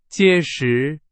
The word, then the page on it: 结实: Chinese